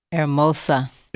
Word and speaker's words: hermosa: How you say beautiful? Hermano? Hermana? – Hermosa. – Hermosa. – Hermano, you said. Oh, no wonder when I said that, somebody looked at me crazy. Hermosa.